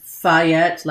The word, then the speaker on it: Fayette: His name was Fayette, like Lafayette.